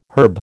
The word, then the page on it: herb: American English